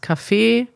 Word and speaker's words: Café: Also der Kaffee, Betonung erste Silbe, kurzes A. Das Kaffee, Betonung zweite Silbe, langes A.